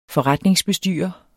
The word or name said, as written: forretningsbestyrer